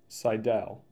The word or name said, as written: Sidell